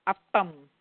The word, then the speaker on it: Appam: Appam.